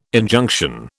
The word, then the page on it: Injunction: British English